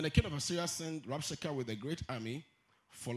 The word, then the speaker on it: Rabshakeh: Then the Rabshakeh, in fact, let me pronounce it. Rabshakeh. The H is silent.